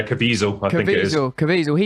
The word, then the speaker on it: Caviezel: Caviezel, am I saying his name right? The lead actor. – Yeah, Caviezel.